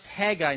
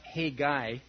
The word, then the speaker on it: Haggai: Haggai, which is pronounced Haggai, not Hey-guy.